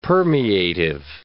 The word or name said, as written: permeative